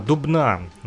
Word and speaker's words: Дубна: Особенно, я думаю, будет интересно тем, кто вот, э, любит граффити, да, э, я сказал Дубна, Дубна называется этот город, на самом деле, э, чтобы вы вот правильно слышали и называли ударение, а то я вот с ошибками иногда говорю.